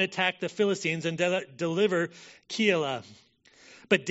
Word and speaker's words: Keilah: Then they told David, saying, "Behold, the Philistines are fighting against Keilah, that's how you pronounce it, Keilah, and they are plundering the threshing floors.